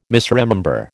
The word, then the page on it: misremember: American English